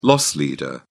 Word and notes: loss leader: British English

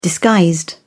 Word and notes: disguised: British English

